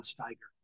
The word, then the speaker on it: Steiger: Don Owen had a tag team that he was working to get over, a couple of Germans, Kurt and Karl von Steiger. – Steiger. – I don't know how they pronounce it. I think it's Steiger, yeah. So. – S-T-I-E-G-E-R.